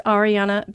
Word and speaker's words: Ariana: So it's Ariana Barrett. Ariana spelled like Ariana Grande with one N, and Barrett is B-A-H as in happy, R-E-T.